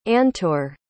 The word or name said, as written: antor